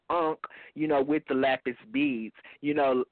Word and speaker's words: lapis lazuli: And this is a, I don't know how to pronounce some of these crystals, but a lapis, what, how do you pronounce it? – It's lapis lazuli.